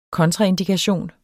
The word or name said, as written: kontraindikation